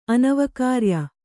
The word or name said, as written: ಅನವಕಾರ್ಯ